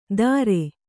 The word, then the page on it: ದರೆ: Kannada